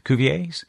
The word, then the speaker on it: Cuvier's: Cuvier's? Beaked whales. Been spotted in the Mariana Trench. Yeah, there is an S. The S is silent.